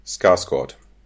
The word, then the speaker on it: Skarsgård: Skarsgård. – Okay. Das wird mit O ja. gesprochen. – Ja, ich weiß. Okay. Muss ich auch sagen, das war eine richtige Aussprache.